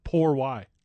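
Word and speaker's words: por qué: For why? Wait, did I say that wrong? Poor why? – Yeah, that's better. It's closer. 50% closer.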